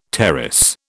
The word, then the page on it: terrace: British English